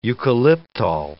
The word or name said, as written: eucalyptole